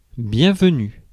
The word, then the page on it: welcome: /ˈwɛl.kəm/